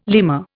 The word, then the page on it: lemur: Indian